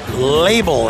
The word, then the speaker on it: Labl: Label, and it's spelled like this, L-A-B-L. Label.